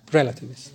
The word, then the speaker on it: relativists: The accent on the first E, relativists.